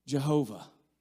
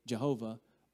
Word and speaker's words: Jehovah: Jehovah. We don't really know if that's the way it should be pronounced. Some say Yahweh.